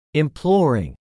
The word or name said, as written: imploring